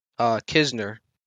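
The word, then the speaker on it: Knizner: What about Andrew Knizer? Is that how you say his name? – Kisner. – Kisner, bro.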